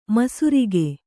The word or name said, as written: ಮಿಸಿರಿಕೆ